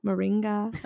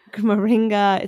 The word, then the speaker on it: Moringa: Moringa. Am I saying it right, Susie? – I think so, yeah. – Moringa. Okay, great. She always knows how to pronounce when I don't. Moringa.